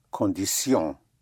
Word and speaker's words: condition: Condition would be condition. The T-I-O-N and S-I-O-N is pronounced sion, You don't have the sh sound, the English sh, shin, but sion.